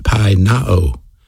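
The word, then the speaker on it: peinaō: The word for hunger is the word pinao, P-I-E-N-A-O is how you pronounce it, pinao.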